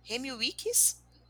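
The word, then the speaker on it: Remi Weekes: É o Remy Weekly. Gente, como é que pronuncia isso aqui? Remy Wicks.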